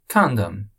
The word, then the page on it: Condom: ingles